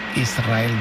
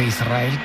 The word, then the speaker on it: Israel: Y de diminutivo, de chiquitito, Israel, que ella quería, porque Israel creo que le sonaba muy grande, ¿no? Israel, ¿no? – Y la S con la R es difícil de pronunciar, la gente dice Israel.